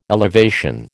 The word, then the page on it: elevation: American English